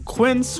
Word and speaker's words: Quince: Today Explained support today comes from Quince, which rhymes with since but is spelt with a Q-U.